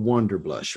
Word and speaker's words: Wanderblush: So, you know, and the name of the blog is called Wonder Blush, right? – Yes.